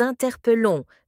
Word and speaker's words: interpellons: Ceci veut dire que l'on écrit « nous interpellons », mais que l'on prononce « élons » et « non élons », comme s'il n'y avait qu'un seul « l ».